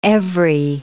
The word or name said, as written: every